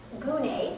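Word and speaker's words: gune (γυνή): In the Greek, the word is gune, that's how it's pronounced, and it means woman.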